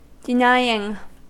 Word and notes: denying: American English

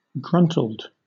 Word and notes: gruntled: British English

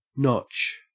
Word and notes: notch: Australian English

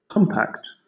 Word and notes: compact: British English

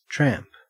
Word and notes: tramp: Australian English

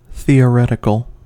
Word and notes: theoretical: American English

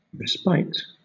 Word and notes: respite: British English

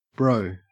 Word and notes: bro: Australian English

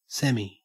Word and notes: semi: Australian English

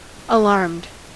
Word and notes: alarmed: American English

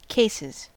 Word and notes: cases: American English

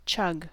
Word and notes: chug: American English

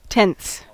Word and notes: tenths: American English

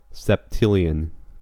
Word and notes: septillion: American English

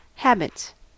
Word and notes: habits: American English

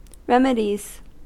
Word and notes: remedies: American English

